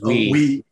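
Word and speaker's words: Uy: Yeah, I'm not 100% sure how you pronounce his last name. It's U-Y. – It's Wee. – Oh, it's Wee.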